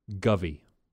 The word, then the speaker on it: guvy: I'm saying it wrong. Govy. – Govy? – Govy. Okay, G-U-V-Y. Govy.